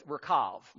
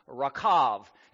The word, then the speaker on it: Rahab: Which in Hebrew it's Rakav. Those are two long A's. They're not short A's. They're long A's. Rakav is how you, and that's a V at the end instead of a B.